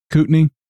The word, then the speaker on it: Kootenai: Jim Osman sent a picture from Kootenai. Is that how you pronounce it? – Yeah, I believe. Kootenai. I think it's Kootenai. – But it's AI. – I know. But I think that's how it's pronounced.